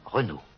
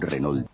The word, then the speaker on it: Renault: ¿Cómo se dice él? – Renault. Un Renault. – Renault. – Un Renault. – Renault. – ¿Geno o Renault? Está ahí el tema.